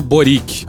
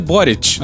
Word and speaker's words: Boric: Pois é, o Bolsonaro se referiu ao futuro presidente chileno como o tal do Boric. A pronúncia correta é Boric.